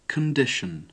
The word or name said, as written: condition